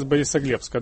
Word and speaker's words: Борисоглебска: Сергей из Борисоглебска.